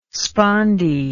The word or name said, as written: spondee